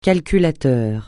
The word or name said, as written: calculateur